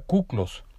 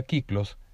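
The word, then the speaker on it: kiklos (κύκλος): Uno de ellos pensaba que se había griego, y descubrió la palabra cuclos, aunque realmente se pronuncia kiklos, que quiere decir círculo.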